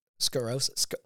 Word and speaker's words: sclerosis: multiple sclerosis. I'm sorry. I'm mispronouncing this, but scoliosis? – Multiple sclerosis. – Sclerosis. Yeah. Yes.